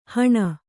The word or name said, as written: ಹುಣ್